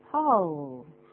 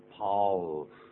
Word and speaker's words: Paul: 可是呢是 P-A-U-L, 这是个人的名字，有个 L, 怎么发音呢？ – Paul. Paul. – 所以舌尖要卷上去哦，这要向上扬啊，叫 Paul, 这样子，不要念的 Paul 啊，或者是念 Paul, 这发音都不对的。